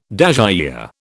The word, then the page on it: dasyure: British English